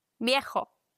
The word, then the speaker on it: viejo: Alt, viejo. Übrigens, V wird im Spanischen wie ein leichtes B ausgesprochen.